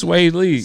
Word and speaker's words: Swae Lee: I never knew how to pronounce that shit. – Like, it's Swae Lee.